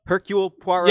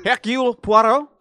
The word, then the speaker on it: Hercule Poirot: Hercule Poirot? – Yeah, you guys saying it.